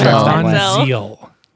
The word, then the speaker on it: Manziel: It's Manziel. – Okay, Manziel, whatever.